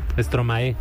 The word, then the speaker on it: Stromae: ¿Stromae se pronuncia así? – Stromae. – Stromae. Uh-huh. Gracias por la corrección. Vámonos con esto. Stromae, Algo Dance.